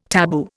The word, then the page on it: tabu: Indian